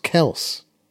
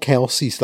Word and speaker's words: Kelce: where Jason Kelce said his second name's pronounced Kels. – Kels? – Kels. Travis and Jason, it's pronounced Kels, but they've been called Kelsey's like since they went into college.